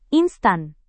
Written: instan

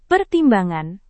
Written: pertimbangan

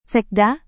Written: sekda

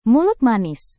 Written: mulut manis

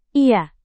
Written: iya

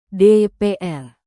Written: dpr

